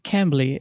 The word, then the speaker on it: Cambly: Então, é, mandíbula, como que é o esquema da Cambly? Tô falando certo? É Cambly? – Cambly. – É, Cambly. É, obrigado, Cambly.